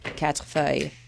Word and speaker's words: quatre feuilles: You can pronounce it the French way if you would like. – Quatre feuilles. – Meaning four leaves. – But the American pronunciation works too.